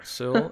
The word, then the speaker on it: Sewell: Sewell, I think that's how you say it.